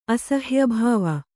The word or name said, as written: ಅಸಹ್ಯಾಭಾವ